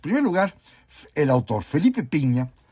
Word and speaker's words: Pigna: Otro más, miren, una novela de otro cariz, de otro tipo distinto, argentina, la escribe un gran historiador, Felipe Pigna, o Piña, con G, Pigna se escribe, pero creo que se pronuncia Piña, y se llama Conspiración en Londres.